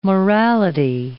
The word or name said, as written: morality